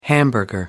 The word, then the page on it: hamburger: American English